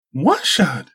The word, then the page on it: Wa-shad: Galindri